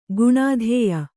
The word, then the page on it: ಗುಣಾಧೇಯ: kannada